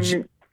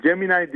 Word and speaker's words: Gemini: Acaba Google nasıl cevap verecek diye e, tahmin edilirken veya düşünülürken Google Gemini. Doğru mu telaffuz ediyorum? Öncelikle onu sorayım. Gemini, Gemini diye söyletiliyor.